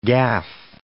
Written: gaff

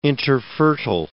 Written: interfertile

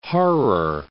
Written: Harer

